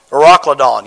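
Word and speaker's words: Euroclydon: Verse 14 says, but not long after there rose a tempestuous wind called an Ecuador. I promise you, at home, I learn how to pronounce these things, and I get to church, and I cannot pronounce them. Eurocladon. Yes, thank you.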